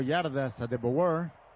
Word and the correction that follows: Adebawore: ¿Lo dije bien, Enrique? – Adebawore. – Adebawore, sí.